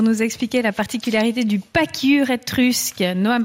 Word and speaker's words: pachyure: Le pacure, qui s'écrit P-A-C-H-Y-U-R-E, si vous voulez le chercher sur Internet. Le pacure étrusque, oui, faites gaffe, est le plus léger mammifère existant.